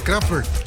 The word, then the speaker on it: Kraftwerk: Mire, le felicito porque está pronunciando muy bien el nombre de Kraftwerk. – Así es. – Sí, como lo está haciendo como alemán nativo.